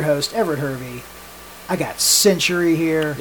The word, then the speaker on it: Syntury: Did you just say Synergy? – I can't remember. – Pause. Rewind the tape. It's false advertisement already. – Give me the correct pronunciation. – The correct pronunciation is Syntury.